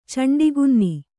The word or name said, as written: ಚಂಡಕನ್ನೆ